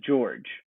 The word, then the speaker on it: Georges: Basketball Reference has it just as George. – Okay, so it's just George. – That the pronunciation is just George.